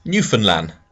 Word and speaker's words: Newfoundland: A quick pronunciation point. Newfoundland is pronounced Newfoundland. They are the Welsh of Canada in that no one can understand their accent.